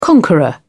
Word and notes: conqueror: British English

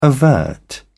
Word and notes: overt: British English

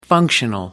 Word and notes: functional: American English

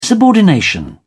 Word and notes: subordination: British English

